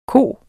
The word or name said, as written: ko